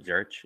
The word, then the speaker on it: Zurich: That's how you say it, right? Zurich? – I think so. Zurich, yeah. – Zurich, yeah. – Zurich. The H might be silent. – Yeah. The C's silent. Zurich.